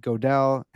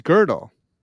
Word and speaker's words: Gödel: How's it pronounced? – It's Gödel. – What? – It's got the two little dots on top. It's German, man. It's Gödel. It's not Gödel.